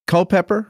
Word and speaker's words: Culpeper: Now, you are the rector, the head guy, the top dog at St. Stephen's Episcopal Church in Culpeper, Virginia. Culpeper, right? Is that how I pronounce it? – Yeah, that's correct.